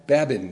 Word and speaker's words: Babin: I hope I'm pronouncing it right. Babin, that's it, B-A-B-I-N.